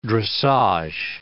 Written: dressage

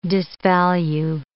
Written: disvalue

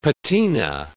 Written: patina

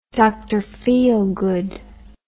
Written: Dr. Feelgood

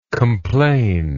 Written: complainingness

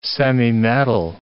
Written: semimetal

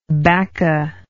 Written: bacca

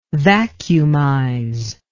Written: vacuumize

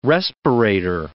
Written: respirator

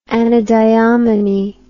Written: anadyomene

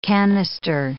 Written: cannister

